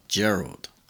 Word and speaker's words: Gerald: y hay un nombre para las chicas que es Geraldine, así que es como Geraldine, pero sin in, o sea, Gerald. Pero en inglés se pronuncia Gerald.